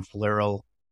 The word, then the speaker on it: Flierl: So Tom, and my last name is spelled F as in Frank, L-I-E-R-L. So Tom Flierl. The name of the company is Amla, A-M-L-A, Amla Commerce.